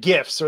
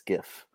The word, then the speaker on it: GIF: I believe the creator of it even said it's GIF. Even though, I think, GIF. No, they've said this. The creator of it even said. – Well, it stands for graphical image format. Hard G.